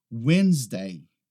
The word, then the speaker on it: Wednesday: A próxima é esta aqui, Wednesday. Wednesday. Aqui a gente não pronuncia Wednesday, a gente não pronuncia aquele D no início. Então não é Wednesday, é simplesmente Wednesday.